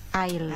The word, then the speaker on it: aisle: No. There's L. The I should hear the L. – Esli. – No, we don't pronounce the S. – Aisle. – Okay. Aisle.